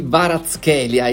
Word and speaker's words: Kvaratskhelia: Dovrebbe pronunciarsi Kvaratskhelia, il georgiano del Napoli, che sembra essere uno dei principali obiettivi per la prossima stagione come sostituto erede di Insigne.